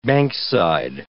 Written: bankside

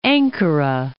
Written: Ankara

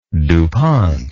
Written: dupont

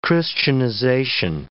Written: Christianization

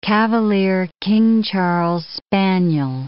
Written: Cavalier King Charles spaniel

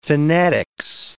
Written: phenetics

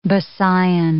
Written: Bisayan

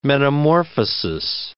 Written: metamorphosis

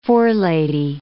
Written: forelady

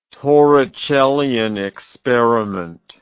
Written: torricellian experiment

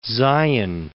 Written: Zion National Park

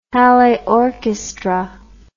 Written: Halle Orchestra

